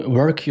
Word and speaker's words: Werk: And for those who wants to know, Werk, you spell it like twerk, but without the T, so that's a good start.